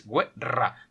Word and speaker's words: guerra: No, es guerra. Devi meter eh, fuerte la lengua el paladar. ¿Cómo se dice el paladar? Esto aquí. – Palato. Palato. – Devi meter la lengua sul palato.